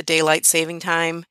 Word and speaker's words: daylight saving time: And we're still freezing to death this morning, even with the daylight saving time. Notice I said that right, daylight saving time. I have to make myself say it. There's no S on the saving.